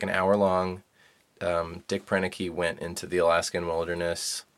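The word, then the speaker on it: Proenneke: Prenicke.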